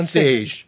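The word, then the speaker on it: Šejić: I heard it was Sage. Like he actually put a pronunciation on his Twitter. – Stefan Sage. Yeah, Sejic. – But he leaves the IC off. He says it's how you like Sean Connery would pronounce his name, and I'm like, oh, Sage.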